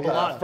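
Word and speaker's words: balut: who would like to know if anyone here would eat ballot? I'm not sure how to pronounce that. – Oh, balot. – Fertilized duck eggs.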